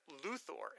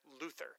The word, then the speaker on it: Luthor: And he also mentions, I also appreciated how you later pronounced Lex Luthor, emphasis on the O. On TV, e.g. Smallville and Supergirl, it's typically incorrectly pronounced Luther.